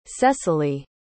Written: Sessilee

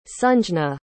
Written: sanjna

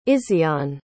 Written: izyan

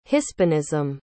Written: hispanism

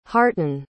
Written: hartun

